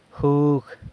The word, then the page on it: jug: sanlucasquiavini